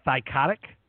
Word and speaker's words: Thycotic: Which, I'm pretty sure I spelled it, pronounced that right. Thycotic? – That is correct.